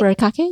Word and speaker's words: furikake: But I am happy to update our listeners that my new Japanese student has won the family over with noritama or furikake. Am I saying that right? – A furikake, yes.